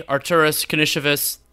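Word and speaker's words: Karnišovas: Officially, Arturas Kanishevis, is that the correct pronunciation? That's how I've had it in my head. – Yes, I believe that is correct.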